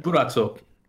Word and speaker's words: Purrazzo: Deonna Purrazzo. Is that how you pronounce that last name? I think that's it. Yeah, I know of her. – She's really good. – Purrazzo. – There you go.